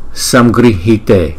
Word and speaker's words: Samgrihite: Samgrihite, chữ R cái dấu nặng này chúng ta đọc là ri, ri, ri. Samgrihite, Samgrihite, Samgrihite.